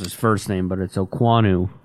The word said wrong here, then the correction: Okonkwo: Okonkwo. – Okonkwo? – Yes.